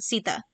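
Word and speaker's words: Sethe: and that's how you pronounce it, Sita. So it's Seth, and then there's the at the end as a result from an accent.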